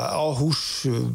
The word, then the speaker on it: Aarhus: Ja, Aarhus... vind ik ook wel aardig, ja. Maar dat is wel goed uitspreken. Ja. Ik wilde zeggen hoe Chinees, maar dat is natuurlijk perfect nu.